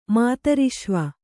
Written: ಮಾತರಿಶ್ವ

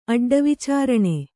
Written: ಅಡ್ಡವಿಚಾರಣೆ

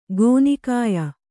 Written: ಗೋನಿಕಾಯ

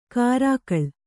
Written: ಕರ್ಕಳ

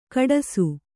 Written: ಕುಟ್ಟಿಸು

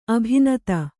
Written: ಅಭಿನೂತೆ

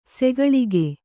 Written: ಸಕಾಲಿಕ

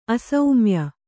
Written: ಅಸೌಮ್ಯ

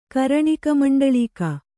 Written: ಕರಣಿಕಮಂಡಳೀಕ

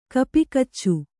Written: ಕಪಿಕಚ್ಚು

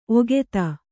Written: ಒಗೆತ

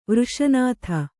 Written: ವೃಷನಾಥ

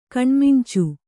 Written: ಕಣ್ಮಿಂಚು

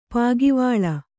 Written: ಪಾಗಿವಾಳ

